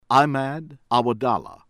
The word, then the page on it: AL-MUSSLIT, ADNAN ABDULLAH ABID: AHD- nahn ahb- DUH- lah AH- bihd al moos- LEET